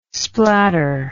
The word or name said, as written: splatter